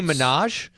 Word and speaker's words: Minaj: Anyway, uh, Majah helped raise Nicki doubts. Minaj. Minaj. Okay. Is that Nicki Minaj, the the famous singer? – Isn't Maybe.